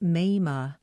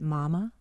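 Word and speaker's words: Mamah: Mema is a nickname for Martha and is pronounced Mema. It's a name that puzzles when first encountered. People ask, is that Mama, like Mother? – Mema was a mama.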